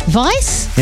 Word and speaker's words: Weisz: In other trending news, Brendan Fraser and Rachel Weisz, or Weiss, how do you spell it? – Weiss? – Yeah, like Edelweiss. – Yeah, Edelweiss.